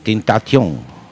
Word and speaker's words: Tentation: Bueno, pues vamos con otro de los discos que para mí fueron de lo mejor del año, con los franceses Tentation. Tentation. – Vamos a ponernos a pronunciar en el idioma.